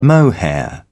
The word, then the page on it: mohair: British English